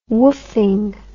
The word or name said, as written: woofing